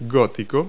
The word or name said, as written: gothic